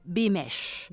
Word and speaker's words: Beamish: Se escribe Beamish, pero es inglesa, se dice Bimesh.